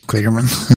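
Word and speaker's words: Kligerman: Parker Killinger, or Killingman, he's Clearman. – Clearman, thank you.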